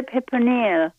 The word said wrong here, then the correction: fipronil: How do you pronounce it again? – Fipronil. I think it starts with an F, but I can't be positive. – Well, fipronil. – Yeah, here it is. It's fipronil.